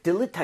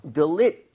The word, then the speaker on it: delete: 근데 delete 할때 L-E의 강세 하나만 찍어주세요. 얘를 너무 delete 하지 마시고요. 앞에가 약간 들처럼 힘이 좀 빠진다는 느낌? – 오. – So delete 이렇게 올라간다고 생각하시면 될것 같아요.